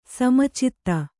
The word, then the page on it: ಸಮುಚಿತ: samucita